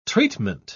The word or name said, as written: treatment